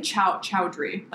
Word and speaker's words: Chaudhry: Name? Chaudhry. Like Chaudhry. Chauda. Chaudhry. Bikram Chaudhry.